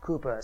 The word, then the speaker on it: Cowper: Now, it's spelled Cowper, but it's pronounced Cooper, so I'm told.